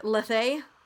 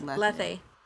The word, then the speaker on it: Lethe: Kylo's world craft is called, what would I say there, Lethe? – I think it's Lethe. – Oh, I always thought it was Leth. – No, the Greeks, I think, like to pronounce their last E.